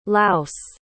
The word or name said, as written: laos